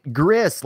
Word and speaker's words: Gris: You said it right, Gris, like Chris.